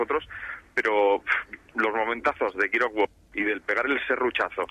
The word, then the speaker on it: Gears of War: Y sí, ahora que era por Game Gears. O sea, bueno, que. O sea, Gears of War, ahora lo he dicho bien, maldita dislexia.